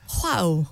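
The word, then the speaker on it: João: What's the first name? – Oh, sorry, Juano Cancelo. – What? I can't hear that. What? How are you pronouncing the first name? – Juao. – Juao? – Juao Cancelo.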